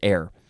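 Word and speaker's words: e'er: Before we had een, that was the adverb with the apostrophe, and now we have, how do you pronounce this, air? I think you pronounce it air. It is E apostrophe E-R, adverb, again, from the 13th century.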